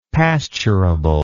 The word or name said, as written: pasturable